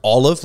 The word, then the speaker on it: Aleph: It's A L E P H, pronounced uh, pronounced olive, like all of us.